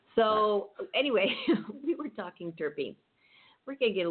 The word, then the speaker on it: terpenes: About 140 of these belong to a large class of aromatic organic hydrocompounds known as terpenes, pronounced terpenes. It's spelled T-E-R-P-E-N-E-S.